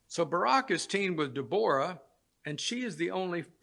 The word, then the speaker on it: Deborah: Barak's story is found in Judges 4 and 5, and he was teamed with Deborah, and we usually would say Deborah, but it's actually pronounced Deborah.